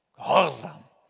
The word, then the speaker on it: Gehorsam: wobei man Gehorsam mit preußisch scharfem R aussprechen muss. – Gehorsam.